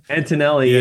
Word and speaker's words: Antonelli: Antonelli? Isn't that the guy's last name? – Antonelli, yeah.